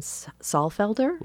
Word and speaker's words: Saalfelder: and that is a woman composer named Catherine Saalfelder. – Mm-hmm. – I think I have that correctly pronounced. – Yep.